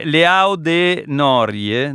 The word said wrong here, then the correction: Leão do Norte: No. – Leão do Norte.